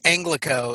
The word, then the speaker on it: ANGLICO: It's 1st Angelico.